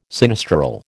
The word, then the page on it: sinistral: American English